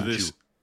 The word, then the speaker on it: jus: And like the one I was talking about with the red wine jus. Is that if that's how you pronounce it? I don't know. Some of it is.